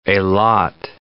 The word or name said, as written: Eilat